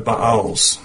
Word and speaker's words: Baal: Then the children of Israel did evil in the sight of the Lord and served the Baals. That's the way it should be pronounced, not Baals. Baals is what you find out in the country, hay, you know. It's technically Baal.